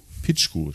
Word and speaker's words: Picchu: Über einen Gebirgspass auf alten Inka-Wegen nach Machu Picchu. Ne? – Picchu spricht man.